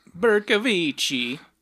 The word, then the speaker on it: Bercovichy: Because there's Vichy France, and that's when the Nazis were kind of holding the whole thing. So that's where I'm getting Vichy from. The rest, I think it's how you pronounce Berko. Bercovichy.